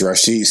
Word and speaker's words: Rasheed: My name is Rasheed Stevens.